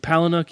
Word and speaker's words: Palahniuk: But this story really was broken and amplified because, now how do we say this guy's name now? – Palahniuk, yeah, okay, mm-hmm. good.